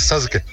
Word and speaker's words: Sasuke: Hasta donde yo sé, en japonés la SU, la U se pronuncia muy corta. Por eso suena a Sasuke. Pero realmente es Sasuke.